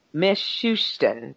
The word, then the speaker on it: Mishustin: Mishustin, Oh, ah, Mishustin, that's his name.